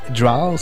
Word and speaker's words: Drow: C'est sûr, certain qu'au Québec, on a tendance à dire Drow ou Drow. La bonne prononciation, c'est Drow, si je me trompe exact.